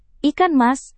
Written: ikan mas

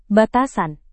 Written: batasan

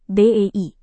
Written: bei

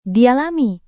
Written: dialami

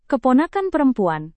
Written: keponakan perempuan

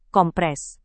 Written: kompres

